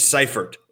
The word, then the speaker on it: Seifert: His name is Seifert. It is S-E-I, but it is pronounced S-Y, like sci-fi for Seifert.